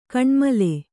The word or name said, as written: ಘನಮೂಲ